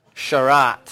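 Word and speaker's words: Sharat (שרת): So how would we say this word? Sharat. Sharat. Very good.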